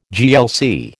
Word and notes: GLC: American English

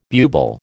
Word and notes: bubal: American English

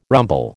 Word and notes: rumble: American English

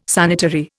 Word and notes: sanitary: Indian

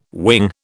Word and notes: wing: British English

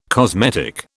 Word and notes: cosmetic: British English

